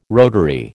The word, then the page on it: rotary: American English